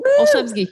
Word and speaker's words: Olszewski: Olszewski, you got it.